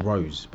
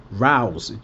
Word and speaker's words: rows: I always read it as rose, but it's row, like cow. Cow bow.